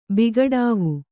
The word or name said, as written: ಬಿಗಡಾವು